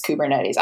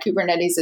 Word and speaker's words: Kubernetes: Kubernetes is one part of the problem and not making everything crash for everybody. Did I say that right? – You were close.